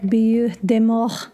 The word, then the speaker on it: Butte des Morts: Now, if you've noticed the way I've been pronouncing Butte des Morts, it's because it was given by French colonial settlers, most likely in the early 1700s.